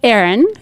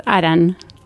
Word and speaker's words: Aran: And how do most Americans say your name? – Aran. But in Basque is Aran.